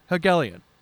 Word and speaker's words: Helgelien: H-E-L-G-E-L-I-E-N. Hegelian? Hegelian. – Yeah. Not close Hegelian.